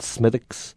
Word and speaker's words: Smithwick's: We have a lovely ruby red ale down in a city called Kilkenny called Smithwick's. S-M-I-T-H-W-I-C-K-S, which all tourists to Ireland pronounce Smithwick's.